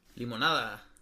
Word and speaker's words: limonada: Limonada. – Fuck you. – Say it right, limonada. It says limonada on it. It doesn't say lemonade.